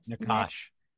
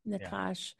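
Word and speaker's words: Nachash: His next book, The Seed of Nakash, I think I'm not saying that right. – Nakash. – Thank you. Nakash.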